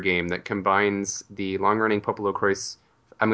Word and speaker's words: Popolocrois: The official word is that it's pronounced Popolocrois, but it looks like a French word to me. Yeah, like they pronounce the S in the game. – That's obnoxious. – So I don't know, Popolocroy is what it sounds like to me. But I'll say Popolocrois just because that's how they say it in the game.